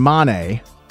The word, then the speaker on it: Mane: M-A-N-E.